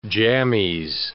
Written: jammies

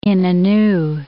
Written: Inonu

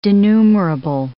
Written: denumerable

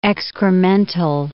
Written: excremental

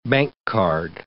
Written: bank card

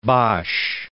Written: Bosch

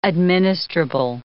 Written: administrable

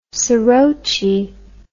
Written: soroche

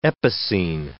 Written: epicene

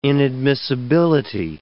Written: inadmissibility

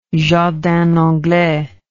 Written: jardin anglais

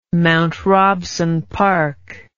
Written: mount robson park

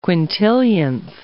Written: quintillionth

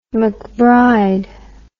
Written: mcbride

